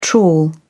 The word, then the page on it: trawl: British English